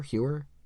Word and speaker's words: Heuer: So for those who haven't, Nintendo and luxury watch maker Tag, is it Heuer? It's Heuer, right? That's how you say it? Heuer? They're Swiss, so I'm not...